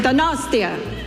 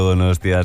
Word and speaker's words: Donostia: Donostia. Okay, yeah. – Pues, es que ricasco, Donostia, es que ricasco.